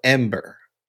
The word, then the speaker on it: Embr: There's this little golden nugget called Ember, and it's spelled EMBR.